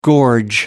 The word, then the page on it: gorge: American English